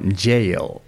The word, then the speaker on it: Yale: Del, del episodio pasado, Yale. – Así es como se pronuncia.